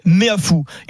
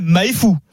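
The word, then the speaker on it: Meafou: L'arbitre me dit, il s'appelle Emmanuel Méafou. Il ne s'appelle pas Emmanuel Maïfou. Il s'appelle Méafou. C'est Pierrot qui a bien prononcé.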